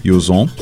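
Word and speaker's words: yüz on: É um dos legados que o baiano deixou, a pronúncia de como se dizer 110 em turco, Yuzon.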